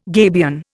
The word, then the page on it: gabion: Indian